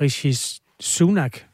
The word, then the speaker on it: Rishi Sunak: En intern måling i det konservative parti viser, at medlemmerne helst ser den tidligere øh, finansminister Rishi Sunak. Udtaler jeg det rigtigt? – Det gør du.